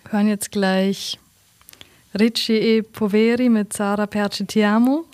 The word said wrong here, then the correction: Perché Ti Amo: Habe ich das richtig ausgesprochen? – Perché Ti Amo? – Oh.